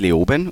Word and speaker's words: Leoben: Also wenn man es hochdeutsch spricht, Leoben und sonst Leoben. Und sonst Leon.